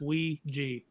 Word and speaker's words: Ouija: That is the correct pronunciation. It's Ouija.